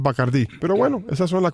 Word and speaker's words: Bacardí: A propósito, mucha gente en Cuba no sabe que uno de los patrocinadores principales del canal de pelota es Bacardí. Uh-huh. Aunque aquí pecan y le dicen Bacardi, porque por supuesto todo tiene que ser aquí así. – Aunque la pone acento en la I muy claro.